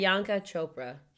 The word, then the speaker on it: Priyanka Chopra: Who I think set them up was Priyanka Chopra. Is that how you pronounce her name? – Priyanka Chopra.